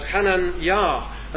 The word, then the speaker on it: Hananiah: The Hebrew form is Hananiah, or Hananiah, as I am told it is pronounced.